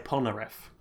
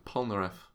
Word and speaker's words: Polnareff: How do you actually pronounce his last name? – I say Polnareff. – Polnareff. Okay. I assume that's the correct pronunciation.